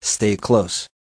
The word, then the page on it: stay close: American English